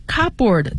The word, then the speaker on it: cupboard: Cupboard 其实就是壁橱，它是 cup C-U-P 然后 B-O-A-R-D，但是 cupboard 这个 P 是不发音的，因为它连读了，所以呢它就不出声音了。